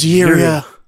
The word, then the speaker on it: Jirya: J-I-R-Y-A is the spelling, which, and I'm just trying to phonetic that without a hard J. – Yeah, sorry. – It's pronounced Jiria.